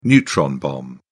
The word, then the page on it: neutron bomb: British English